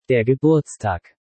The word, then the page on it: Der Geburtstag: German